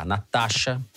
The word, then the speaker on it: Nastassja: O nome dela se escreve da seguinte maneira: N-A-S-T-A-S-S-J-A, Natasha Martin.